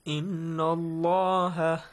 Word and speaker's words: إن الله: Inna Allah. – And by the way, you extend the sound of inna. Hussein, read it. – Inna Allah. – Ahsantum. Inna Allah.